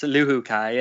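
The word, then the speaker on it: Luhukay: Is it Luhak? – Jos Luhuka, yeah.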